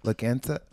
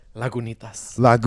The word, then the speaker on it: Lagunitas: Look, I know people might be a little bit more familiar with Laganza? – Lagunitas. – How do you say it? – Lagunitas.